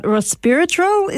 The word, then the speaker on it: resveratrol: Sophia, you did mention this morning about resveratrol.